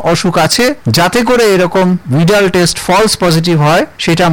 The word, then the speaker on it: Widal: এনারা একটা পরীক্ষা করেন, যে পরীক্ষাটাকে ভিডাল পরীক্ষা বলে, অনেকে উচ্চারণ ভুল করে এটাকে ওয়াইডাল পরীক্ষাও বলে, WIDAL, কিন্তু উচ্চারণটা ভিডাল টেস্ট।